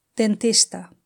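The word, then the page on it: dentista: Spanish (Spain)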